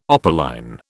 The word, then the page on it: opaline: British English